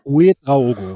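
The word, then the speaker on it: Ouédraogo: Uedraogo, so. – Uedraogo, richtig. Uedraogo, Mann. – Mit Betonung auf dem E.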